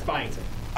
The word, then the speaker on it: Byington: Yeah, it's Byington.